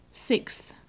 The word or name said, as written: sixth